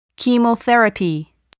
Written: chemotherapy

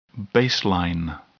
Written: baseline

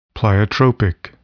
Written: pleiotropic

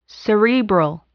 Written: cerebral

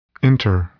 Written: enter

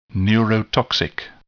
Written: neurotoxic

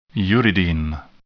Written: uridine

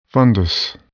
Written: fundus

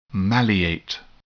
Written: maleate

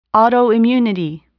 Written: autoimmunity

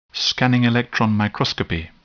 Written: scanning electron microscopy

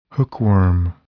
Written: hookworm

